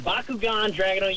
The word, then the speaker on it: Bakugan Dragonoid: Am I saying the name right? Because Austin, I'm sure we'll get it right. What's up, Cam? – How are we doing, gentlemen? You are absolutely saying Bakugan Dragonoid.